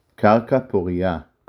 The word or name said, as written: קרקע פורייה